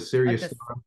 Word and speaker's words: Sirius: Sirius, and it's Sirius spelled like the Sirius. – Like the yeah. – Okay. – Yeah. Sirius.